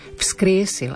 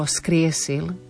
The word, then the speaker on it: vzkriesil: Vzkriesil, aby nebolo skriesil, aby nám zaznelo to F, vzkriesil.